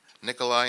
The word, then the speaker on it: Nikolai: She was raised in rural Alaska Native villages, including Koyukuk, Kaniganek, Naknik, Nikolai, and Selowik. How'd I do in the pronunciation there? Good.